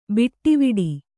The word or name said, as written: ಬಟ್ವಾಡೆ